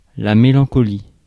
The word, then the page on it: mélancolie: France; [me.lɑ̃.kɔ.li]